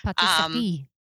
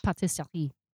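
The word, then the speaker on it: pâtisserie: Patisserie. – Say it, because I'm terrible at accents. – Patisserie.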